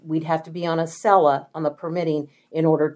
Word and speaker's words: Accela: Oh, and it's, am I pronouncing it right? Acela? A-C-C-E-L-A? – Acela, yeah.